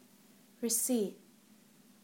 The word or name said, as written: receipt